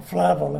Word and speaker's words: Flavel: John Flavel, or Flavel as some pronounce it, was a Puritan.